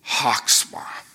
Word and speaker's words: chokhmah: It's one of those words that you have to start from the back of your throat to say it properly. Chokhmah. It's kind of like ruach, the Spirit of the Lord. You really have to almost just like you're clearing your throat.